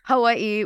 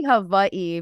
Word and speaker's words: Hawaii: Hawaii. Well, it's actually Hawaii, but I'm going to say it like Hawaii.